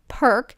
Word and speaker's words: Perque: Perk, it's spelled P-E-R-Q-U-E, Perk Potent C Guard.